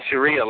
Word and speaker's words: Sharia: What is it, Shahara law? Is that how they pronounce it? – Yeah, the Sharia law